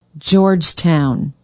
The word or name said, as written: Georgetown